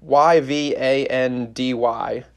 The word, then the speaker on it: Yvandy: It's spelled Y V A N D Y is his first name, Yvandy, and last name is Rigby.